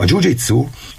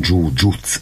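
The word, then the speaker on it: jujutsu: A jiu-jitsu, amit Japánban is hívják, egyébként japánosan jiu-jutsu a rendes kiejtés, de ugye halljuk kiejteni jiu-jitsunak, mint hogy mi is mondjuk, ez ilyen inkább angolos, vagy a németes, meg a brazil területen a jiu-jitsu.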